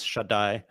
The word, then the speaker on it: Shaddai: And you mentioned that sort of the biblical God is a shed. Is that how you say it? – Yeah. So one of the names for the Bible has many names for God. One of them is Shaddai, which actually etymologically is probably connected to breasts.